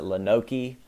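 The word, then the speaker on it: Lonoke: They're little lexicon pieces on how to pronounce some of these places, because I've heard there's a city called Lone Oak, but it's not spelled like Lone, L-O-A-N-E. L-O-N-E-O-A-K, it's L-O-N-O-K-E. Well, I've heard people pronounce it Lenoke.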